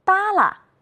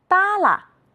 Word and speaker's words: 耷拉: 扇子，是的，耷拉，竖着。你发现了什么？有的同学说，老师，后一个字都要读成轻声。你们真会观察，我们再来读一读。扇子，是的，耷拉，竖着。